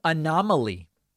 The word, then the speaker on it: anomaly: Go to the Merriam-Webster dictionary, type in the word A-N-O-M-A-L-Y, and you will see in the pronunciation section, both pronunciations are fine. Anomaly, like a garden gnome, anomaly. Both are fine.